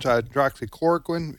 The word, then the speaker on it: hydroxychloroquine: And if these two medications, hydroxychloroquine, it took me a year to learn how to say that.